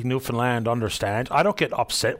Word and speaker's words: Newfoundland: So I'm throwing an idea out for the government and anybody else who'd like to comment on it, that we take the O out of the found part of Newfoundland and F-U-N-D, Newfoundland. That's the way we pronounce it.